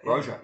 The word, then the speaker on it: Broja: It was a nice goal from, I don't know how to pronounce his name, Broja? – I think you've done well there. Broja, yeah.